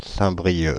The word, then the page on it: Saint-Brieuc: France (Île-de-France); [sɛ̃.bʁi.jø]